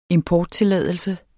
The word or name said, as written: importtilladelse